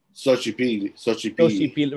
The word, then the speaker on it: Xochipilli: Xochipilli. – Right. Soch, like Xochil.